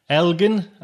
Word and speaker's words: Elgin: It's coming in with Poetry Planet number 10, Englin and the Dwarf Star Awards. Is that how showcase? Is that how you pronounce it, Diane? Elgin and the Dwarf Star Awards showcase.